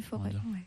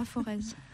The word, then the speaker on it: Forez: La plaine du Forêt. Avec un Z, mais il ne faut pas prononcer le Z. – Ouais. Voilà. – On ne dit pas Forez.